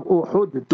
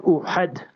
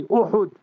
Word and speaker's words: Uhud: Third year of the Hijrah, just outside Medina Munawwara, in the month of Shawwal, the Battle of Uhud took place, not Uhud. Learn to pronounce the words correctly, Uhud.